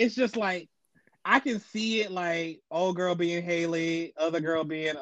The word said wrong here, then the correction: Halle: I know you did not pronounce Halle Berry's name as Haley. – Halle, my bad.